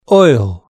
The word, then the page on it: oil: British English